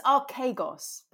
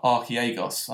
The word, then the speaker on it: Archegos: I thought it was Archegos, but I could be wrong. – I think it was Ar- Archegos. I think it's a silent. I think there's a silent J in there. it's like Yargin.